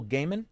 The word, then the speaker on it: Gaiman: This is for Brian, from a critically acclaimed or beloved creator like Jack Kirby, Neil Gaiman.